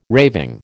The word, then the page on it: raving: American English